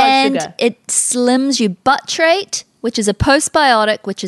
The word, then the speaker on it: butyrate: Butyrate? – Yeah, butyrate.